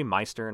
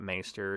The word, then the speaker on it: Maester: It should be Meister, not Maester.